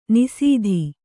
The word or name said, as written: ನಿಸೀಧಿ